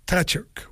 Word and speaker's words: Tkachuk: Tkachuk, oui, on le prononce comme ça.